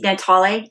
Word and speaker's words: Natale: Is that how you say his name? – Natale. Yeah. Natale.